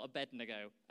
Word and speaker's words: Abednego: These three men are Shadrach, Meshach, and Abednego, or Abednego, as actually I probably should say, because that's how it's spelt.